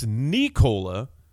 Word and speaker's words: Nikola: Not Vukovic, not Vucevic, Vucevic. And it's Nikola, emphasis on the knee, first name. Interestingly, different from MVP frontrunner Nikola Jokic.